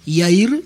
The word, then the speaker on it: Yair: Él se llama Yair, ahí la pronunciación está Yair, con Y, Yair, así con I latina, después Y e I latina. Yair, uh-huh, sería.